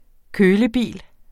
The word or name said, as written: kølebil